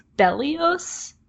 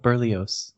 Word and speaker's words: Berlioz: It's not Toulouse, it's Berlioz? – Oh yeah, Berlioz.